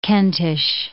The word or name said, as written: Kentish